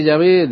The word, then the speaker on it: Yahvé: Parece que Yahová es un tipo más reciente de pronunciación de la época, tal vez del siglo XVI, y que Yahvé de hecho es la correcta pronunciación del nombre de Dios.